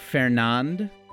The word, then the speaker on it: Fernand: That is spelled like Fernand, but it's Fernand.